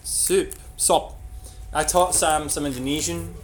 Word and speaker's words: sop: Okay, so soup in Indonesian is sop, which is just S-O-P, which I think is wonderful. You just take the U out and it becomes sop. Sop. – And sop.